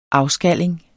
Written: afskalling

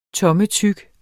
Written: tommetyk

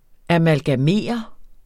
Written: AMALGAMEREDES

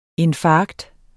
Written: INFARKT

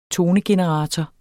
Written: tonegenerator

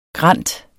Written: grant

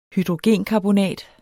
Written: hydrogenkarbonat

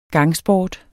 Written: gangsport

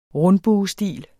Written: rundbuestil

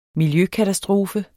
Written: miljøkatastrofe

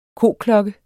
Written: koklokke

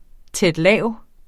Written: tæt-lav

